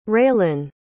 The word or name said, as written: Raylynn